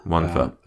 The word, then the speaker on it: Wonfor: I think his name is pronounced Wonfer. – Wonfer, yeah. – W-O-N-F-O-R.